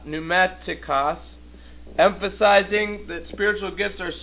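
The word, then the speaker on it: pneumatikos (πνευματικός): The word there is pneumatikos. Okay, and here's how it's spelled. I'm trying to remember my Greek. Okay, that's how it looks. Pneumatikos sounds like pneumatic drill.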